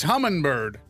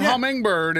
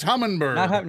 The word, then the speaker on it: Humminbird: I want people to know that it's Humminbird. Not Hummingbird, it's Humminbird. – No. – Right, we're there's no G in there.